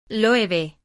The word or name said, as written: Loewe